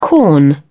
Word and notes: corn: British English

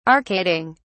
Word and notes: arcading: British English

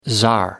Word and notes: tsar: American English

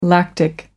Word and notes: lactic: American English

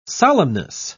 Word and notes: solemnness: British English

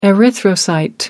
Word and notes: erythrocyte: American English

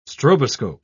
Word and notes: stroboscope: British English

